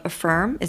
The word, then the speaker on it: Af3irm: So you work for the Coalition Against Trafficking in Women, CATW, and you also are a member of Af3irm.